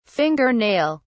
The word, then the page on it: finger nail: British English